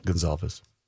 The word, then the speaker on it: Gonsalves: Right-handed pitcher Zach Littell and lefty Steven Gonsalves and Lewis Thorpe added. – Gonsalves. – What did I say? – Gonsalves. – That's you put the wrong emphasis on the wrong syllable.